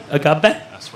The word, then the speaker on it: agave: Now, set here is handcrafted from 100% blue Weber agave. Did I say that right? Agave? – Agave, yeah.